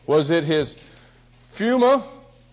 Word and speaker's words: pneuma: Your spirit is pneuma. It may be pronounced pneuma. The P could possibly be silent, but it's pneuma, if I'm enunciating it right.